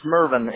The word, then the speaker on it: Mervin: It's Mervin. It's M-E-R-V-I-N.